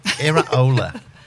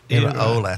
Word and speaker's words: Iraola: Iraola. – What? – Iraola.